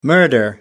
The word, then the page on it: murder: American English